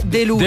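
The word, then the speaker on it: De Louza: Che, allora, si scrive De. – De, de, cioè die. – Die. – Ok. – E poi Louza. – Louza. – L-O-U-Z-A. Che quando lo leggi è delusa.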